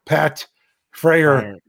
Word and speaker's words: Freiermuth: Pat Freiermuth. It's not Muth. It's Muth. Muth. Yeah. – You know, like more like the Greek letter.